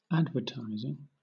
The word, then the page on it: advertising: British English